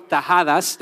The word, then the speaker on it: tajadas: We call them tajadas. That's how you say it in Honduras.